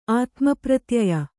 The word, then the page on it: ಆತ್ಮಪ್ರತ್ಯಯ: ātmapratyaya